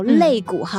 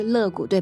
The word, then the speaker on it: 肋骨: 上帝用肋骨所造嘛，嗯嗯、那我们在国内我们都说两肋插刀，肋骨哈。嗯、但是我们取其这个繁体字的发音，我知道台湾国语发音是乐骨，对吧？嗯嗯、呃，所以我们取这个繁体字的发音，是因为这个乐和快乐、喜乐它同音，哦、是是是。